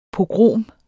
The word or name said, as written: pogrom